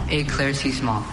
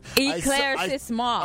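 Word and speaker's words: éclaircissement: A. Claire C. Small. – What? – Is that how it's pronounced? – Eclaircissement.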